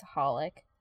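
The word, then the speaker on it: xxxHolic: Is that pronounced XXXXXX Holic or just Holic? – I call it just Holic. But it's triple X.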